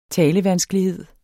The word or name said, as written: talevanskelighed